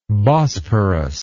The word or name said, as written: bosporian